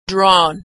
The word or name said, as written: drawn